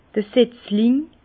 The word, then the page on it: d'r Setzling: Bas-Rhin; Herrlisheim